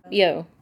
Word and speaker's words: Yeoh: How do you say her name? – Yeoh. – Michelle Yeoh will win, and unfortunately, probably Jamie Lee Curtis.